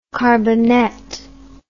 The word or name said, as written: carbonette